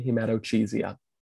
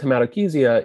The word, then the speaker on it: hematochezia: And please, for all of you out there, do not say hematochezia. – I have had attendings who say hematochezia. So it's very bad. Are accepting of all pronunciations, but we prefer hematochezia.